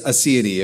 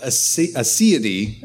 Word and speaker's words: aseity: That is what aseity, and sometimes I might say aseity, that's how the British pronounce it. I listen to D.A. Carson a lot, so it may come out both ways.